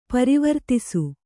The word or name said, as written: ಪ್ರವರ್ತಿಸು